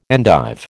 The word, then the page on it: endive: American English